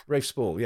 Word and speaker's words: Rafe Spall: And it's a film starring, I suppose the main star is Rafe Spall. Is that how you say his name? – Yeah, Rafe Spall, yeah.